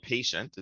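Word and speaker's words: Paytient: I work for a company called Payient. It's spelled with a Y, P-A-Y-T-I-E-N-T.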